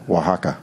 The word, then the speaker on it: Oaxaca: S-M-O-O-X-C-H-I. – So they do it in Mexico. They don't pronounce the X. – Yeah. Mm-hmm. – Oaxaca? – Oaxaca. – Oaxaca. That's what it is.